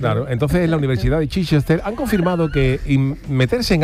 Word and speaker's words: Chichester: Chichester, claro.